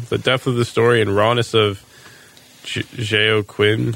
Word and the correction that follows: Joaquin: how do you say that? – Joaquin Phoenix.